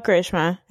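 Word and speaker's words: Karisma: Well, I don't understand because it's spelled Charisma, but we call it Karishma.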